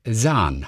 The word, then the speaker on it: Zahn: Jetzt guck mal, Steve Zahn. Ähm, ich habe ein G zu viel. Steve Zahn, okay. Sahn heißt der wohl. Sahn. Mhm, er heißt okay. Sahn.